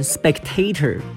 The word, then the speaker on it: spectator: 重音可以放在后面的 a，可以念成 spectator，但是重音也可以放在第音节 e 的地方，就要念成 spectator，spectator 两个念法都可以。